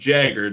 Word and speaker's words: Jager: I'll get Brett with one T a lot, and then my last name gets butchered all the time because it's pronounced Jagger, J-A-G-E-R, but obviously get Jaeger all the time. – Yeah, of course. – Or yeah. – People that spell it J-A-G-G-E-R or J-A-E-G-E-R.